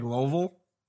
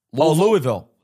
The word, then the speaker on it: Louisville: Looks like Louisville. – Louisville. Did you say Louisville? – That's how it's pronounced.